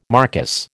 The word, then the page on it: marquis: American English